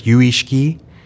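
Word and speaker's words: yuishiki (唯識): In Japanese, and well, Chinese, the Japanese pronunciation of the Chinese term is yuishiki.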